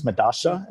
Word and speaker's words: Medalla: Yeah, so the Argentinian pronunciation of Medalla or Medalla is Medasha, and that's what everyone's going with right now.